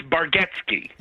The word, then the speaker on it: Bargatze: It's Bargatze. – Why can I not say that? – Well, because it's spelled with two A's and an E, and it sounds like it's two E's and an A. I think of it this the way I figured out how to say it is it's like, do you remember Lethal Weapon 2? I think it was when they had Joe Pesci's character. – Yes. – His name was Leo Getz.